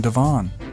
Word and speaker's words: Divon: John Divon.